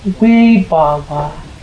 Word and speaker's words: Vibhava: And the third kind is called vibhava tanha. V-I, we's are pronounced like W's. Vibhava, V-I-B-H-A-V-A, Vibhava Tanha.